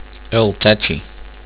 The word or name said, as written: eltakchi